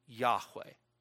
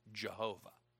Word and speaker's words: Yahweh: Sometimes you'll see it referred to as the tetragrammaton, because in Hebrew it just consists of four letters from which we derive the name Yahweh. Or if you prefer the old German pronunciation, Jehovah.